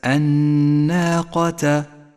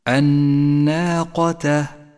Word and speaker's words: الناقة: اقرأ كلمة الناقة، ولاحظ اللام الشمسية التي لونت بالرمادي إشارة إلى عدم نطقها، والنون المشددة التي لونت بالأخضر إشارة إلى حاجتها إلى الغنة، الناقة. الألف مرققة والقاف مفخمة.